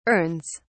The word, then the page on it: urns: British English